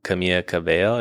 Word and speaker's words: Camila Cabello: Yeah, it's like those two and Taylor Swift Essentials and the odd Camila Cabello. Is that how you say her name? – Yeah, that's pretty good.